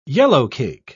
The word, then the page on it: yellowcake: British English